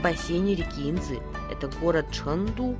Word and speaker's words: 成都: Чхэн читается во втором тоне, а Ту – в первом тоне. – Повторяйте за мной. Чэнду.